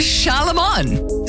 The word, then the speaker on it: Charlemagne: It's Charlemagne.